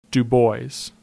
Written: Du Bois

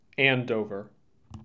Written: Andover

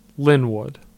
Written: Lynwood

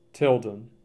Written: Tilden

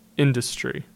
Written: Industry

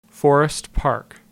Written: Forest Park

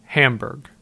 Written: Hamburg

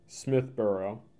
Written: Smithboro

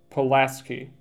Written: Pulaski

